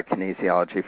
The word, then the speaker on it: kinesiology: And one of the people that I that's a business partner, Darlene, amazing, amazing person, had measured that for her body via kinesiology.